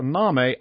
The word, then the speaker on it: Name: Lebanese-Mexican businessman Anwar Name, N-A-M-E, pronounced Name.